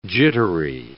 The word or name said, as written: jittery